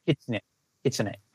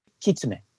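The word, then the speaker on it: Kitsune (きつね): So I'm sure you're saying it right. I'm saying it the way my southern broken mind. Yeah, Kitsune. Yeah, okay. So can you say it one more time the proper way? – Kitsune.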